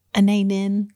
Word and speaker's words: Anaïs Nin: Is it Anaïs Nin. Yes. Am I saying that right? Yes, you I, are.